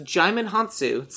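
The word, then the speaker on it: Djimon Hounsou: Jimon Hansu. – Jimon Hansu. It's pronounced Jimon Hansu, says the internet.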